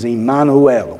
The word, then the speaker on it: Immanuel: "Behold, a virgin shall be with child and shall bear a son, and they shall call his name," and the way it's pronounced in Hebrew is Immanuel, which means God with us.